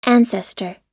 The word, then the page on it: ancestor: American English